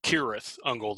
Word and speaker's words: Cirith Ungol: Because it should be Cirith Ungol. The C should have a hard K sound.